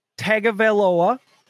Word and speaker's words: Tagovailoa: And then they have quarterback Tua Tagovailoa is what the video said, just like it's spelled. So I don't know why we're arguing about Tua. – The announcers seem to slip in an N, Tua Tagovailoa, and it's like, or a G.